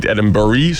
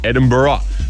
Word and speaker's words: Edinburgh: Het is dus niet Edinburgh, zoals ik zei, maar Edinburgh.